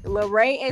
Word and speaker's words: Larae: My Twitter is Neek Laray. My Facebook is Mo Laray. Did I say Snapchat? My Snapchat is Neek Laray. – Can we spell Laray for the people? – Okay. Laray is spelled for everything.